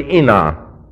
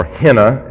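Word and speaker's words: ina (ἵνα): H-I-N-A or I-N-A. The rough breathing is silent in modern Greek, even though it is slightly pronounced, but not to the extent that you have it in most Bible schools.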